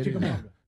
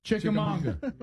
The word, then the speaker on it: Chickamauga: It was, I can't pronounce the name of the, was it Chumachanga or something? – Yeah. – Yeah, something like that. – Chumachanga. – You can't say it. – Chickamauga. – Chickamauga.